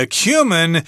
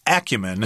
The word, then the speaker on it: acumen: It does say that acumen is actually an acceptable pronunciation of this word, although myself prefer acumen.